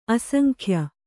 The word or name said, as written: ಅಸಂಖ್ಯ